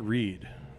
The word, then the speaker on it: Read: It was a red ale pronounced Reed, right? – It was red ale. – It was Reed. Yeah, it was red ale. – Red, yeah. – Written, R-E-A-D.